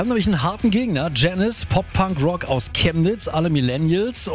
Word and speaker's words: Janiz: Das Einfachste, glaube ich, tatsächlich googeln und im Kopf behalten, dass wir Janice ausgesprochen werden. Und dann findet ihr alles, was über uns zu wissen gibt. – Ja, nur Aussprache bei Google immer schwierig. Schreibweise ist wichtiger. J-A-N-I-Z.